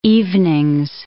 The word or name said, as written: evenings